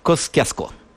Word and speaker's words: Kościuszko: To jeszcze niech pan zgadnie, jak Amerykanie wymawiają słowo Kościuszko. Nazwisko Kościuszko. – No nawet nie będę próbował, to proszę o podpowiedź. – Kosciasko. – No i to się zgadza.